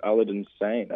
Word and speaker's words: Aladdin Sane: She went, oh, that's, I don't know how to pronounce it, actually, Aladdin Sane, I think.